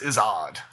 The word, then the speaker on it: Izzard: But he's British, so it's Izzard.